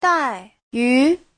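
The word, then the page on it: daiyu: dai4yu2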